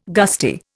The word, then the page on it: gusty: Indian